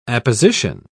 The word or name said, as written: apposition